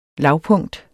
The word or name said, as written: lavpunkt